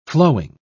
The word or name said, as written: flowing